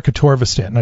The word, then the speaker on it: atorvastatin: atorvastatin. – Yeah. – Is that how you pronounce it? – Yeah, generic atorvastatin.